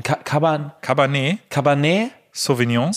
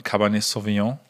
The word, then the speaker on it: Cabernet Sauvignon: Cabernet Sauvignon. – Sauvignon. – Aber Sauvignon Blanc kannst du sagen. – Sauvignon Blanc, ja. – Und Cabernet Sauvignon.